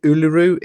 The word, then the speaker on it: Uluru: Get the pronunciation of this wrong, but is it Uluru? Is the right pronunciation? – Mm-hmm.